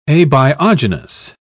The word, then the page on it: abiogenous: British English